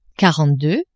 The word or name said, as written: quarante-deux